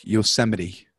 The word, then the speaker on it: Yosemite: It's, it's not Yosmite. It's it's a national park, Yosemite. – I'll I'll tell you what. I'm anti Yosemite right now.